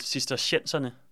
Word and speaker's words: cistercienserne: Jeg mener, de udtales cistercienserne.